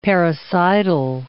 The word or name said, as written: parricidal